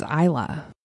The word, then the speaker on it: Isla: And my daughter's name is Isla. But we don't spell it I-L-A, we spell it I-S-L-A, so the S is silent.